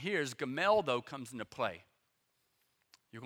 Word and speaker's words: Gamaliel: But a Pharisee in the council named Gamaliel, that's the easiest pronunciation, just Gamaliel. Just remember that, Gamaliel.